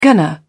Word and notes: gonna: British English

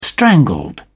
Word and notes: strangled: British English

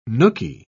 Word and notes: nookie: British English

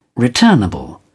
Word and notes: returnable: British English

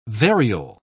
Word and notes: variole: British English

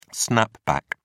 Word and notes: snapback: British English

